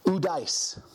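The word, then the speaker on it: udais (οὐδείς): It's pronounced udais. Udais. Okay? Udais is a compound word of two other words.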